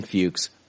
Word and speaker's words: Fuchs: Patreon supporter, Brian Fuchs, rhymes with pukes.